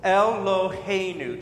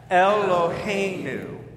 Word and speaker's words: Eloheinu: And then God is Eloheinu. It's four syllables. Eloheinu. Try that. Eloheinu.